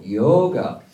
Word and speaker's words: yoga: There's long vowels and short vowels in India, so yoga is a short vowel, and yoga, how Tom pronounced it, is actually the correct way of pronouncing it.